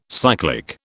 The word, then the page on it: cyclic: British English